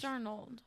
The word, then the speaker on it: Darnold: Sam Darnold, I think I'm pronouncing that right, is expected to start. – Darno. – Darno. – I think it's Darnold.